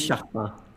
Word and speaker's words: Chartrand: Chartrand.